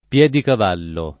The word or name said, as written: Piedicavallo